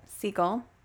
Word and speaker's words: Segel: Segel. Oops. Segel. Okay. I looked it up and listened to somebody and say it. Because I was like, I think I say this wrong always.